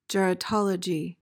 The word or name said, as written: geratology